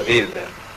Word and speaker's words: Wilder: Corretta pronuncia, Wilder.